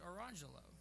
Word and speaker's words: Orange Jello: So the kid's name is Orange Jello, but they pronounce it Orangelo.